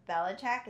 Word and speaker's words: Belichick: So the only thing I'm nervous about is Belichick. Is that how you say his name? – Mm-hmm.